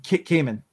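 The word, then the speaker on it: Camon: Cayman. Like Cayman. Like the Cayman Islands.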